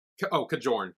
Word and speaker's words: Kajorn: Oh, Kajon. – Kajon or Kajorn? – Well, how do you spell it? – Kajorn. Kajorn.